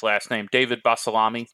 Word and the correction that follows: Bolasomi: Tell me how to say his last name, right? – Bolasomi. – Yeah. Bolasomi. He played at Denton Ryan, I believe, or one of the Denton schools.